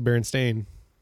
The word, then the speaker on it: Berenstain: Because we all grew up seeing Berenstain Bears, but it's actually Berenstain. – But it's Berenstain. It still said Berenstain, I think. – No, it's... – But I mean, it's spelled Berenstain, but I think you still pronounce it Berenstain Bears.